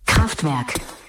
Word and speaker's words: Kraftwerk: donde sobre la melodía podemos escuchar la correcta pronunciación de la palabra Kraftwerk. 3, 2, 1, central eléctrica, Mr. Fly. – Kraftwerk.